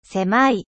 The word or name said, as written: etroit